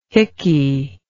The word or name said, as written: hicky